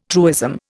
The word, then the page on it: truism: Indian